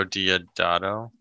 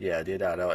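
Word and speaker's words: Deodato: Director Ruggiero I can't pronounce the last names. – Ruggiero Diodato. – Yeah, Diodato, and was charged with obscenity,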